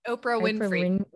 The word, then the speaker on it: Oprah Winfrey: Oprah Winfrey.